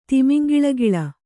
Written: ತಿಮಿಂಗಿಲಗಿಲ

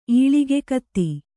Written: ಈಳಿಗೆಕತ್ತಿ

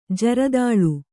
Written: ಜರದಾಳು

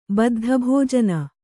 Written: ಬದ್ಧಭೋಜನ

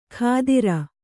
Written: ಖಾದಿರ

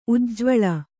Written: ಉಜ್ವಲ

